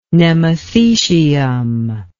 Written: nemathecium

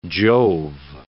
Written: Jove

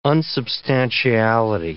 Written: unsubstantiality

